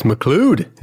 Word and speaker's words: McLeod: That's McLeod, if he's Scottish.